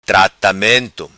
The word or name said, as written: Tratamento